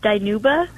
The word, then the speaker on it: Dinuba: Where is Danuba? – It's actually pronounced Dinuba.